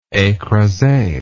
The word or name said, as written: ecrase